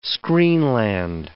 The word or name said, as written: screenland